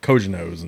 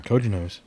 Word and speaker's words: cojones: and he goes, no, I think it's uh, Cojones. – Nice. He goes, okay, it's Cojones. I'll go with that. – That's the uh, that's I, the running gag. It's America, so we pronounce the J.